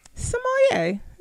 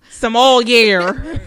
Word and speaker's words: Sommelier: Sommelier. – All right. Sommelier, yes. No. – We're going to come. Sommelier. You're going to add the L.